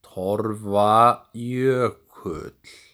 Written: Torfajökull

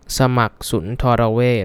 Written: Samak Sundaravej

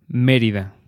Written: Merida

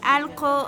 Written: Allqo

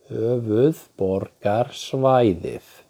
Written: Höfuðborgarsvæðið

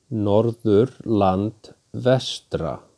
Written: Norðurland vestra

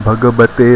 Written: Bhagabate

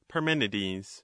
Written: Parmenides

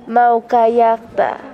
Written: Maukallaqta

